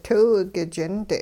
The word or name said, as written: tiw k'ich'int